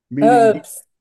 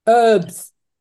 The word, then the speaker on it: herb: Herbs, herbs. – Okay. – There's no such thing as herb, it's herb.